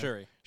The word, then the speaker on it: Shuri: Shuri. – It was interesting to see her. – Young Shuri.